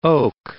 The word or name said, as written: oak